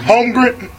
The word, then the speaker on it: Holmgren: Am I saying it right? Holmgren. – Mm-hmm. Holmgren. Holmgren.